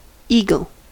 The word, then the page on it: eagle: American English